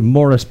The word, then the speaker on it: Maurice: But they put the name together, so if somebody He changed the name from Herbert Blythe to Morris Barrymore, spelled Maurice, but English pronunciation,